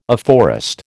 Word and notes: afforest: American English

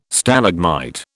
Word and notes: Stalagmite: British English

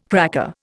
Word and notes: cracker: Indian

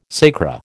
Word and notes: sacra: American English

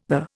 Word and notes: the: Indian

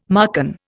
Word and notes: Marcan: Indian

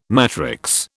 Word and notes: matrix: British English

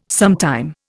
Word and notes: sometime: Indian

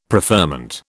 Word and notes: preferment: British English

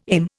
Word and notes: aim: Indian

